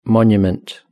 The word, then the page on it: monument: British English